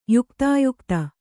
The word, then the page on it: ಯುಕ್ತಾಯುಕ್ತ: yuktāyukta